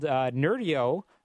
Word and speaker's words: Nerdio: I understand you just rolled out a new platform called Nerdio, or I'm not sure I'm pronouncing that correctly. – You are.